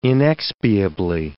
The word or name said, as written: inexpiably